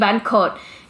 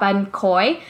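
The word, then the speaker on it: bánh khoái: Let me make sure I'm saying it right. Because that's another dish that I always mispronounce. Because people hear me and they're like, oh, you mean ban khot. And I'm like, no, not ban khot from Vung Tao. I'm talking about ban khoi, which is like a ban sail, but it's thicker and it's crunchier.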